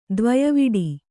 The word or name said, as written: ದ್ವಯವಿಡಿ